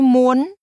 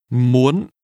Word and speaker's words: muốn: Tôi muốn. Muốn. Muốn. – Want is pronounced with a high rising tone. – Muốn.